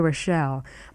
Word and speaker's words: Rochelle: For any further information about VOA programs or a copy of the current VOA program schedule, write to me, Rita Rochelle. My name is spelled R-I-T-A, and Rochelle is spelled R-O-C-H-E-L-L-E.